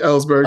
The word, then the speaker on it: Ellsberg: That's right, Ellsberg.